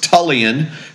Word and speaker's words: Tullian: So it's Tullian.